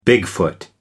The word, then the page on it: bigfoot: American English